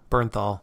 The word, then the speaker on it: Bernthal: Oh, Bernthal.